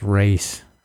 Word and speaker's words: Ra's: It's pronounced race. – Ra's al Ghul. Race. Whatever.